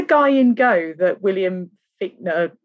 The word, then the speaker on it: Fichtner: It's pronounced Fichtner, but it's like C-H.